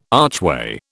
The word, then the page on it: archway: British English